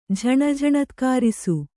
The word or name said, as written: ಝಣಝಣತ್ಕಾರಿಸು